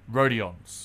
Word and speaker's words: Rodions: How do you pronounce his first name? – Rodions. – Oh, so it's phonetic.